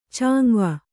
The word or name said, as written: ಚಾಂವ